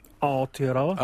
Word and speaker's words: Aotearoa: Aotearoa.